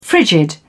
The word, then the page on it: frigid: British English